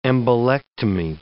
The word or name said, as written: embolectomy